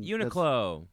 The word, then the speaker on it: Uniqlo: And there was a sale at Uniqlo. I don't know how you say it. The Japanese company. Some Uniqlo. – Uniqlo.